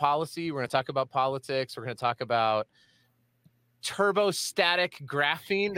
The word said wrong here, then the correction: turbostratic: Did I say that right, Kevin? – Yeah, turbostatic. Yep. – Turbostratic. I already messed it up.